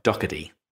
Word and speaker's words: Doherty: David was actually closer to the pronunciation, Doc Hurtie. But yes, in my lazy American, the T sounds like a D. So, Doherty.